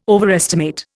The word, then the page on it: overestimate: Indian